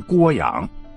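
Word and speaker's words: 涡阳: 就好比涡旋的那个涡，你要念成郭阳。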